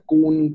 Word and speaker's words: Coon: Puede ser que se, escribe Maine eh, y se pronuncia Kun, creo, pero es C-O-O-N.